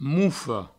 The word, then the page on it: mofa: moufo